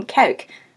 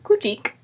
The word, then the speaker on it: Cuijk: and is now in the St. Martinuskerk in Cuijk Oh my gosh, that sound is very hard in Dutch. Um, it's spelled C U I J K. Also, our friend Matteo pronounces it Kujik.